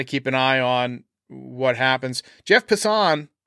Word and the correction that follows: Passan: Why do I always say Passan? That's how I read it, but it's Jeff Passan. I put the emphasis on the wrong syllable.